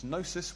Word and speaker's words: gnosis: And so the Gnostics wanted to escape from this nasty world into the spirit realm through secret knowledge, the Greek word for which is gnosis with a silent G, hence the heresy was called Gnosticism.